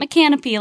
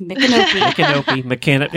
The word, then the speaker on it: Micanopy: Yeah, it's not Micanopy like I was pronouncing it. It's Micanopy.